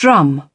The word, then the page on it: drum: British English